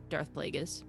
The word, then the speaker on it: Plagueis: I pronounced that wrong. Darth Plagueis.